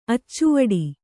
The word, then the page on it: ಅಚ್ಚವಡಿ: Kannada